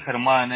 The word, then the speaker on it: Germán: ¿Cómo se dice su nombre? Disculpe. ¿Germán? – En español, Germán es... – Germán, ok. – G-E-R. – So, como German, Germán.